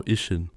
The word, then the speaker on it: Ishin: Ich muss mich noch entschuldigen, dass ich letzte Folge das neue Yakuza-Spiel falsch ausgesprochen habe, denn es heißt tatsächlich Like a Dragon. Ach so, Ishin. Ishin. Ja. Ich glaube, ich habe Kenshin gesagt.